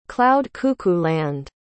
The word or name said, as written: cloud-cuckoo-land